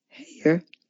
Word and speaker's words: Haigha: Haigha, which Carroll tells us is pronounced to rhyme with Mayor, the personal messenger to the White King and through the looking glass.